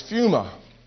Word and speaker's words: pneuma: Your spirit is pneuma. It may be pronounced pneuma. The P could possibly be silent, but it's pneuma, if I'm enunciating it right.